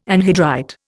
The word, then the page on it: anhydrite: Indian